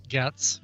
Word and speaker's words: Ghats: It was discovered in the mountains of western Nats or Ghats. How would you say that? Ghats? – Ghats, yeah.